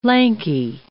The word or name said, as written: lanky